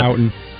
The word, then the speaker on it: Houten: Houten.